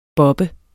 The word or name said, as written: bobbe